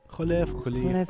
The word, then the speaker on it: Kholeif: Am I saying that correctly? – Kholeif, right? – Kholeif. I like to anglicize it.